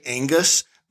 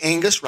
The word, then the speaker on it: Aongus: On bass, and I hope I get this right, I'm just going to say Angus, but it's spelled A-O-N-G-U-S. But I'm going to say Angus Ralston.